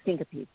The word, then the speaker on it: syncope: Now, I have another question for you about, now, I don't know if I can say this right, but syncope, yes. Okay, I am saying it right.